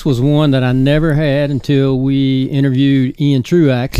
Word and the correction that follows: Truax: Can't say it like that. – Ian Truax. – There you go.